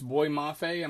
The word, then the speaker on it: Boye Mafe: I heard it pronounced Boye Mafe. I'm going to go with that.